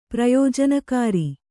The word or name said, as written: ಪ್ರಯೋಜನಕಾರಿ